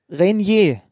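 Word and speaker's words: Reinier: Wie wird er ausgesprochen? Renier.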